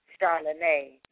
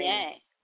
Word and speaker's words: Charlene: My real name Charlene. is Charlene. Ah, yeah. Ah, okay. I like that, Charlene. Yeah, I got that little Creole French going on, so I just pronounce it Charlene.